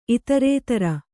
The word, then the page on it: ಇತರೇತರ: itarētara